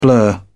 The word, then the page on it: blur: British English